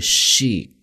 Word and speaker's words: chic: 我们再看 next one, number three, 我们叫做 chic。 Chic，注意啊，这是一个来自法语的一个单词，但是英文中经常会用到，它是一个形容词，时髦的、受欢迎的、流行的这样的一个意思。注意它的读音是 chic，是一个长音 chic。